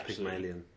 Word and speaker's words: Pygmalion: Yeah, Pygmalion.